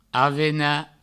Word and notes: avenar: avena; Provençal

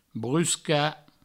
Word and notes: bruscar: brusca

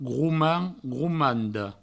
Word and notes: gromand, gromanda: groumand, groumando; Provençal

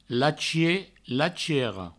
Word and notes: lachier, lachiera: lachie, lachiero; Provençal

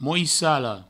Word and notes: moissala: mouissalo

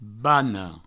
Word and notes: bana: bano; Provençal

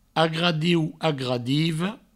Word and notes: agradiu, agradiva: agradieu; agradivo

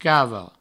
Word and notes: cave: provençal

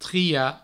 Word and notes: triar: tria

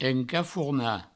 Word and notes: encafornar: encafourna

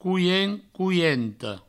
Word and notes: coient: coienta; couient; couiento

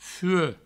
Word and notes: fuec: fue

